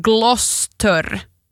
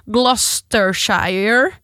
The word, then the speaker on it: Gloucestershire: Alltså M-O-O-R-K-A-M. Morecambe. Och sen Gloucestershire så är Gloucester. Gloucester. Gloucestershire.